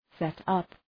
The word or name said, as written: set-up